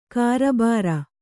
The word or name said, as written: ಕಾರಬಾರು